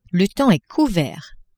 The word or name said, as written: le temps est couvert